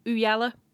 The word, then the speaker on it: Ooyala: Everyone out there, Ooyala. It's how you pronounce it with the British accent, you know.